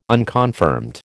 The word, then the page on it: unconfirmed: American English